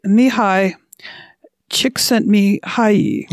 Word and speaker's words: Mihaly Csikszentmihalyi: So it's written by Mihai Csikszentmihalyi.